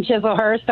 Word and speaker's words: Chislehurst: Crystalhurst, New Jersey. Am I saying that right? – No, I moved from Chislehurst.